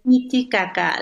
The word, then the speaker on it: Titicaca: And how do you pronounce it for me again? – Lake Titicaca Niticaca.